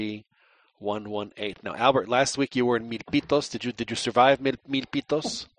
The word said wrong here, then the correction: Milpitas: It's actually Milpitas, so whatever.